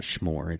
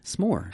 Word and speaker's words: s'more: First of all, there's no H in there. It's not schmore. It's how do you pronounce it? – S'more.